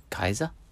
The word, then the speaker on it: Caesar: But someone told me that they didn't have a soft C. It was K. You know, Julius Caesar?